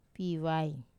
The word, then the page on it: pivai: wamesa